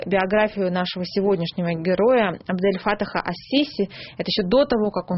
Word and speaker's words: ас-Сиси: Наш герой, Абдель-Фатах Саид Хусейн Халил ас-Сиси, а именно ас-Сиси произносится по-русски, потому что по правилам арабской филологии... – Ну, по-арабски ас-Сиси, потому что «с» — это солнечный харф, артикль редуцируется, харф удвояется. Правильно, ас-Сиси.